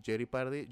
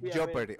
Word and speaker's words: Jeopardy: Porque Joe Party eh, ya existe y Jerry Party, así que Jerry Party, listo.